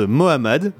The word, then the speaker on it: MMMA: Oui, alors ces groupes à l'étrange patronyme MMMA en fait se prononce Mohamed, mais bon, faut le savoir.